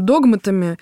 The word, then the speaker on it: догматами: Ну, вот это как-то регулируется, вот я сказала, догматами, правильно говорить, конечно, догматами, или это просто вот общая нравственность и традиция?